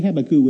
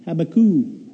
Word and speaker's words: Habakkuk: Well, tonight's scripture that I chose for you is Habakkuk. Everybody likes to say Habakkuk. Say Habakkuk with me. Habakkuk.